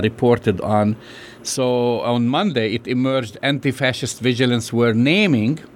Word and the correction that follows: vigilantes: you know, I like how they say it, vigilantes, I'm sorry,